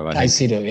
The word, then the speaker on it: Caicedo: Caicedo, yeah.